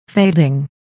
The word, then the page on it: fading: féidiŋ; フェイディン